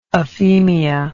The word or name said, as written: aphemia